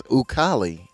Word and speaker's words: Ukali: It's your boy UKALI Ukali. – That is the first time he's ever used that introduction. – I gotta spell it because Ukali.